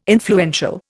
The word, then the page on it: influential: Indian